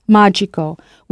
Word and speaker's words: magico: Words ending in I-C in English also make a trasformazione to I-C-O. So if drastic becomes drastico, how would you say magic? – Magico. – Of course, magico, with that stress on the first syllable.